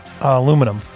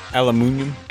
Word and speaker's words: aluminium: Dude, you know what drives me crazy is when British people and Australian people pronounce aluminum. – Aluminium? – Aluminium.